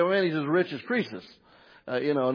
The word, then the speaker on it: Croesus: Now, it's pronounced Croesus, but it's C-R-O-E-S-U-S.